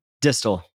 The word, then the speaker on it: Distel: Distel.